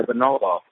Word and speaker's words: bonobo: We're also very closely related to the bonobo. I believe that's how it's pronounced.